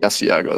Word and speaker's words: Asiago: Asiago, that's right.